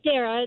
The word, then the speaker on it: Dara: My name is actually Dara. It's spelled with a D, like Delta.